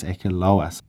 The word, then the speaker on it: Akaloa: So I always thought it was Akaroa, but actually looking on the map, it's Akaroa, which is spelt A-K-A-L-O-A. So the R is replaced by the L, and I always thought it was Little Akaroa, but it's not.